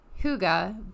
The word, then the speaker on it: hygge: So if you were just to see this word, you would not assume that it's pronounced Hygge, but it is.